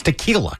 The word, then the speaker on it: tequila: He thought that's no, it's because he's an idiot. It's tequila. Come on.